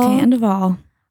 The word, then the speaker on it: Scandoval: Scandoval.